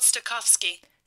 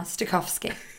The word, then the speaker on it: Stokowski: Leopold Stokowski.